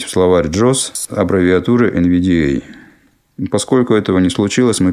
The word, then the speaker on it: NVDA: Аббревиатура NVDA обычно произносится с ударением на «э», поэтому мы перед буквой «э» напишем в данном поле редактирования пробел.